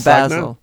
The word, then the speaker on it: Basil: And then basil with a short A. – Odd. – That's sort of the British pronunciation of like Basil Rathbone. Mm. The name Basil.